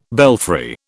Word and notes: belfry: British English

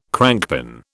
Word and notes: crankpin: British English

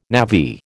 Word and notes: navvy: American English

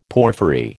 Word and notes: porphyry: American English

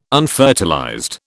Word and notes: unfertilized: British English

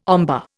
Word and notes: ombre: Indian

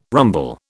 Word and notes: rumble: British English

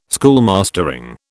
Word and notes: schoolmastering: British English

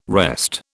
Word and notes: wrest: British English

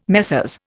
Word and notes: Messrs: Indian

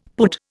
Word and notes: pud: Indian

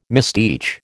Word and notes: misteach: American English

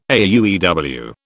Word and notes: AUEW: British English

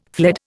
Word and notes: fled: Indian

